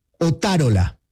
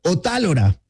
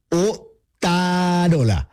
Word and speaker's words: Otárola: Javier Otárola, no Javier Otálora, como podría pensarse, sino así, Javier Otárola,